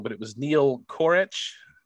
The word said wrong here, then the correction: Kroch: Neil Kroch. – Kroch. – Neil Kroch. Yes.